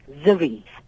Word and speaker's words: Zari: It's spelled Z-A-R-I and Zari in India, it's pronounced Ziri.